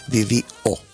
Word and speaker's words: Ndidi O: Su segundo apellido es Onunwulu, pero ella se pone, se escribe N-D-D, entonces sabría que esa N casi no se pronuncia, Didi O, y este álbum se llama Move Together.